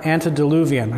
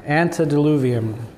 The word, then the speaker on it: antediluvian: Antediluvian. I said it right that time. Antediluvian.